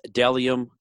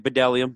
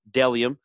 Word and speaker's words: Bdelliom: My other sponsor is Delium Clothing. It's B-D-E-L-L-I-O-M. Not so good this time. But it's pronounced Delium. I know it looks like Bedellium, but it's Delium. B-D-E-L-L-I-O-M.